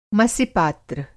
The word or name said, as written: मसि-पात्र